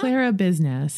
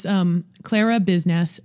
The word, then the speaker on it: Clara Biznass: Clara Biznass, C-L-A-R-A. It's not my real name. It was my grandmother's name. It came from taking care of business. So it's Clara Biznass, C-L-A-R-A-B-I-Z-N-A-S-S, C-L-A-R-A-B-I-Z-N-S on nice.